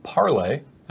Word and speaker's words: parley: It's an elf warrior with parley, as we are told wizards prefers it to be pronounced.